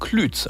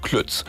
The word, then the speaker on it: Klütz: Aber auch Lübs und Klütz, also gesprochen mit langem Ü, die werden immer mal wieder zu Lübs und Klütz.